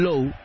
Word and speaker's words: Lowe: o en este sí es Nathaniel Lowe. El otro día hablábamos, tiene el mismo apellido de Brandon, el segunda base, se escribe igual y todo, L-O-W-E, pero Brandon se pronuncia Brandon Lao, este es Brandon Lowe, este es Nathaniel Lowe, mejor dicho.